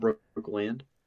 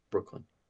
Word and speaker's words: Brooklyn: Like, I don't think the Celtics stand a chance going against Brooklyn. I think that's going to be a gentleman's sweep. – Brooklyn. – Nothing, just a little minor gripe. It's not Brookland, it's Brooklyn.